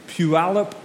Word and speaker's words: Puyallup: This guy's name is Emmett, and he lives in Puyallup, Washington. It's not how you would say it if you read it, but that's how it's said.